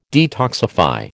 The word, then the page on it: detoxify: American English